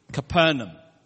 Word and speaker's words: Capernaum: The correct pronunciation of Capernaum is actually Capernaum.Capernaum. – 是加百农吗 It's the village of Nahum. – The what? – Village of Nahum. Capernaum. – Naomi. Nahum. – But in English, we call it Capernaum.